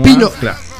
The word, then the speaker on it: Pinot: Claro, eso te iba a decir, tiene una pronunciación, eh, porque yo le digo Pinot Noir y es Pinot Noir. Pino, claro.